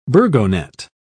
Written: burgonet

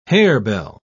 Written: harebell

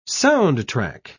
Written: soundtrack

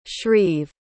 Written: shreeve

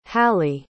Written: hali

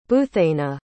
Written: buthaynah

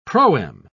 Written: proem

